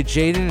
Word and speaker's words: JXDN: And by the way, Jaden is spelled J-X-D-N, in case you've seen it before.